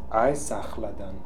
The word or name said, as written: Aiesakhaldan